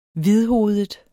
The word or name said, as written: hvidhovedet